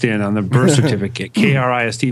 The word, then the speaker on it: Krist: And with us, our special guest for the day, Krist Novoselic. That's how he told me to pronounce it. You know, pronounce the T, too?